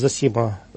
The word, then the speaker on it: Зосима: Вкратце, схиархимандрит Зосима Сокур, я ставлю ударение на первый склад, это духовник Донецкой епархии на протяжении более 10 лет.